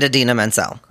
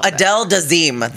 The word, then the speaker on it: Idina Menzel: Oh, Adele Dazeem. – That's what he said.